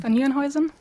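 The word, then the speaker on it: van Nieuwenhuizen: je vais essayer de ne pas écorcher son nom, von Nieuwenhuizen, de c'est bon, j'ai réussi. – Von Nieuwenhuizen. – Ah voilà, vous le dites beaucoup mieux que moi, de l'université de Stony Brook pour leur théorie émise il y a plus de 40 ans en 1976 sur la supergravité.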